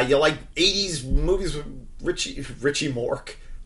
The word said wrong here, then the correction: Mickey Rourke: God damn it. I can't say his name. – Mickey Rourke? – Go watch it. Rourke is actually kind of hard to say. – Rourke. It's the Rourke.